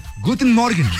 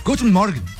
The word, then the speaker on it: Guten Morgen: En alemán se dice Guten Morgen. Guten Morgen. Escuchemos cómo se dice buenos días en alemán. – Guten Morgen. – Eso, sí. Guten Morgen.